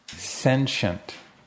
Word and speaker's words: sentient: 이때 T라고 읽지 않고 sentient라고 읽어야 되겠죠.